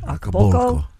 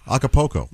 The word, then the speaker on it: Acapulco: What is the correct way to pronounce that? – Acapulco. – Caribbean. – I don't know that I hit the L at all. Acapulco.